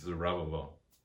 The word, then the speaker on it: Zerubbabel: It's Zerubbabel.